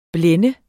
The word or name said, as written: blænde